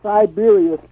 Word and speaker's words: Cyberia: Siberia spelled with a C. – Okay, got it. I love it. – Like C-Y-B-E-R-I-A.